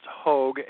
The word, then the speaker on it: Hogg: It's actually pronounced Hogg, H-O-G-G, but it's commonly mispronounced. My ancestors were lowland pig farmers, and we were named after our profession. So for some reason, it's pronounced Hogg, but now you know a little bit of my background.